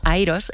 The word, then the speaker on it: IROS: En inglés la pronunciamos como IROS.